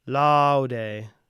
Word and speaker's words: laude: Quia major omni laude. Nec laudare sufficis. So for those diphthongs, you're going to spend most of your time on the ah sound. Aude, aude, laude.